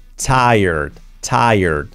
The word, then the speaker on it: tired: Tired, tired. Como taller de coche, me voy al tire, pues con acento en la primera sílaba, tired, y poniendo una D al final, tired.